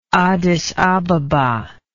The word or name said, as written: Addis Ababa